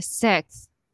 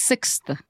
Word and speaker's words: sixth: I used to say like sis. And I was like, I thought and I thought I had now been saying it right, like saying six.